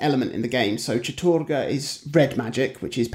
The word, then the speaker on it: Chattur'gha: Either there, there's Chattur'gha, Ulyaoth, and Xel'lotath.